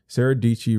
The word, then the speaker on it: Dietschy: Sarah Dietschy rhymes with peachy. I got to say it like that because if I don't, it'll be a sin.